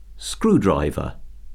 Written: screwdriver

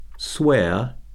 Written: swear

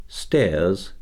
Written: stairs

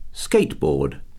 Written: skateboard

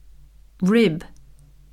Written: rib

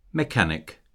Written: mechanic

